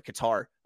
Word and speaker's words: Qatar: Oh, sorry, Qatar.